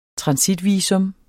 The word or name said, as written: transitvisum